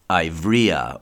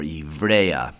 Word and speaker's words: Ivrea: who was a member of the House of Ivrea or Ivrea, which is spelled I-V-R-E-A.